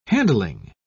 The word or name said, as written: handling